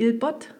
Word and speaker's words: Ilbot: Und dann auch nicht zu unterschätzen, der Blick auf den Teller, ein Stück weiter runterrollen, beim Alpbeizli Ilbot, habe ich es richtig ausgesprochen? – Genau.